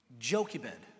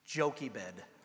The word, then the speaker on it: Jochebed: Jochebed. That's how you pronounce it. Trust me. I know. I'm not a doctor, but I do know that it sounds like Tempur-Pedic come out with a silly mattress. Jochebed.